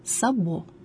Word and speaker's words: сабо: Ударение в слове сабо падает на последний слог – сабо.